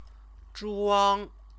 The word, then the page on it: zhuang: chinese